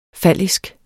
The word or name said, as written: fallisk